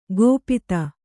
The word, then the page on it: ಗೋಪಿತ: gōpita